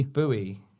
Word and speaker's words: buoy: Boy is pronounced to rhyme with toy, while buoy is pronounced buoy. So they don't sound alike.